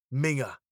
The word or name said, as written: minger